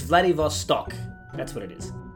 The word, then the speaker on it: Vladivostok: Hang on, I'm looking it up. Vladivostok. Vostok. That's what it is. Vladivostok. – Yeah, Vladivostok. Yeah, I can say it now. – That's it. – Vladivostok. – Yep, that's it. That's how you say it.